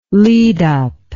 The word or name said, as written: lead-up